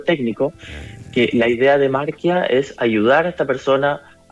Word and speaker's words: Marquia: Sí, Marquia, que se escribe con Q. – Marquia. – IA.cl, sí. – .cl, ya, sin una U entre medio, como diría el idioma español. Marquia, así nomás.